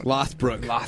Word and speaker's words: Lothbrok: Is it Lothbrok? – Lothbrok.